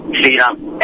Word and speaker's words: Sriram: Sheeram. S-R-I-R-A-M.